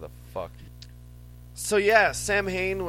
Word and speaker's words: Samhain: Is that it's interesting because Samhain is a Celtic word. It's technically pronounced Samhain. – Really? – Yeah, and I see it, and I can't not say Samhain. So we know we're doing it wrong, but we're gonna keep doing it wrong.